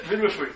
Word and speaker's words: midwifery: She said, midwifery. I said, what are you talking about? She said, no, that's the right way to pronounce it.